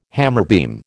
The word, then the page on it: hammerbeam: American English